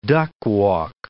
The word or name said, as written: duckwalk